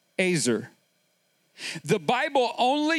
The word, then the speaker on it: ezer: the Hebrew word ezer, E-Z-E-R is how we would spell it, is the Hebrew word ezer.